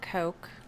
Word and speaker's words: Cok: It's Amber Coke.